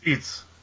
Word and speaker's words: Eets: Are you saying Eats? – Yes, Eats.